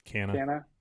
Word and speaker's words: Canha: Canha.